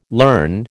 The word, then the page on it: learn: American English